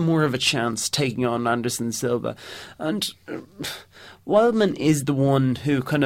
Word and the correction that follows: Weidman: Wildman or Weidman? – Weidman. There's an awful pronunciation error in my lifestyle.